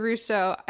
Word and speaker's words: Ruscio: It's Ruscio. You nailed it. – Dr. Ruscio.